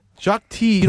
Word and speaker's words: Jacques Tits: Jacques T. I guess you don't pronounce the consonant in the last consonant in French. Jacques T.